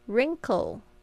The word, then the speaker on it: wrinkle: OK，然后 wrinkle free， wrinkle 特别注意一下，它这个是 WR 开头的字，但是呢 W 不发音， wrinkle 就是指皱纹， wrinkle free 就是不起皱纹的。